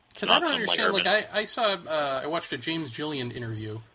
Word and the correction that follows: Gilliland: I think it's Gilliland.